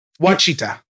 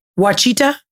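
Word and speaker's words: Ouachita: I can't pronounce it. Alchichita Mountains? I don't fucking know. – Where? Wachita. – Wachita? – Yeah.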